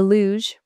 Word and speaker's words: luge: Part go-kart, part sled, the luge, pronounced as use, is a gravity ride for all ages with a braking system that allows you to control how fast you travel downslope.